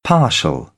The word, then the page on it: partial: British English; ɑː